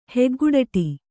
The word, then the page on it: ಹೊಗೆಕಡ್ಡಿ: hoge kaḍḍi